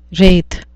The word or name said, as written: wraith